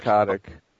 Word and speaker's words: Kotick: Is it just me, or is it every time Bobby how do you pronounce his name? – Bobby Kotick.